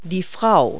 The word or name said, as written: woman